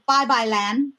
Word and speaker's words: Bye-Bye Land: We Are No Longer Bye-Bye Land.